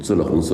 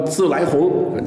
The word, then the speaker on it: 自来红: 京式月饼里当然最多的就是这种自了红自了白。老北京啊，看你是不是北京人，就看你怎么念这个三个字，是吧？老北京一定叫自了红自了白，读清音。如果你说自来红，那不是老北京人。